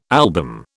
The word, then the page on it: album: British English